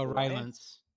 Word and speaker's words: Rylance: Oh, Rylance.